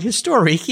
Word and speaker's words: Historique: Historique, yeah.